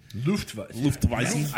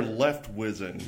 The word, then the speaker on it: Luftweizen: Enjoy their Leftwizen. – Yes, precisely. You can also say it that way. It's alternate pronunciation.